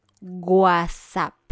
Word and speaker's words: guasap: Y hablando de WhatsApp, ahora en español necesitas decir WhatsApp. WhatsApp. – ¿Qué? – WhatsApp. ¡Qué horrible! – Se escribe G-U-A-S-A-P.